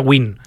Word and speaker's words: Nguyen: Muchos jugadores americanos vietnamitas tienen esa, pero se pronuncia Win. Se pronuncia W-I-N, se pronuncia Win.